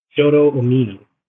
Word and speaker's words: Shota Umino: Shota Umino.